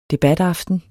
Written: debataften

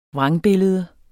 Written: VRANGBILLEDERNES

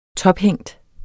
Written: tophængt

